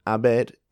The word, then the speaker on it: haber: So, that was H-A-B-E-R. So, haber. What you may have guessed by now is that the H, if it starts a word, is not pronounced. Mm-hmm. So, for example, how do you say today? – Hoy. – Hoy. And the spelling is H-O-Y. It's a similar case there. You don't pronounce the H.